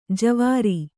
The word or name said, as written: ಜುವ್ವಾರೆ